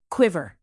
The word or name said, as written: quiver